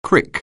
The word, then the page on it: crick: anglais